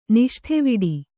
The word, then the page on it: ನಿಷ್ಠೆವಿಡಿ: Kannada